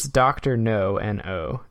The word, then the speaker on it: Dr. No: I realized the way I pronounced that made it sound like Dr. No's. – Yeah, I was picturing him having like a really big nose. – It's Dr. No, N-O.